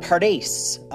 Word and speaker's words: Pardes: Okay, so the first letter of each word, P-R-D-S, is taken, and then the vowels are added in for pronunciation, giving the word Pardes, meaning garden or orchard.